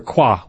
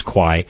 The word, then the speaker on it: Kwai: And this was, in a sense, the real story of the bridge on River Kwa, which is the way the Thai pronounced this river in Thailand during the Second World War, but most people know it as Kwai.